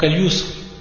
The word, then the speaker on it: Al-Yusr: Donc Al-Yusr bil-ya, ou Al-Usr bil-ayn. Et le reste, c'est la même prononciation.